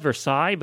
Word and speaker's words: Versailles: It's spelled Versailles, but nobody can say that in Kentucky, so we say Versailles.